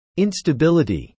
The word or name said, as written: instability